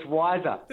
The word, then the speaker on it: Wisr: Now, but also, even the business you work for, is it Wizzer? Is that the right pronunciation? W-I-S-R? – It's Wiser. – There you go. – Actually. – See, Wiser.